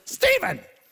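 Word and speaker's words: Stephen: If I was in trouble, it was Steven. And it's spelled with a PH, which makes no sense at all, because PH doesn't make a V sound.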